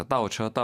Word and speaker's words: 倒车: 이때는 이제 倒가 발음이 사성입니다. 그래서 倒车, 倒车, 倒车.